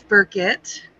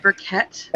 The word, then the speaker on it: Burkett: I say it's Burkett. Not Burkett. – And when you say I don't put the accent there. I put it on the other side of it.